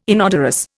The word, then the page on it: inodorous: Indian